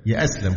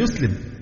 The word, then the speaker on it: يسلم: يأسلم فلان عايز يأسلم، هي الفعل يسلم، إنما يأسلم دي كلمة من اختراع الناس في هذا العصر من الركاكة وضعف اللغة الشديد،